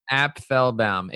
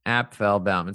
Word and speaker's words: Apfelbaum: It's Applebaum, A-P-F-E-L-B-A-U-M. So it's three syllables. – Cool. So I mean, meaning the F is not silent, basically. – Applebaum. It's not silent. Some people say Applebaum, whatever.